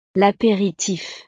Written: l'aperitif

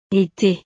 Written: ete

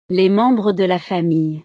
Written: les membres de la famille

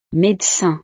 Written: médecin

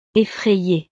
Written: effraye

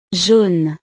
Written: jaune

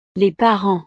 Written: les parents

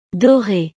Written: dore

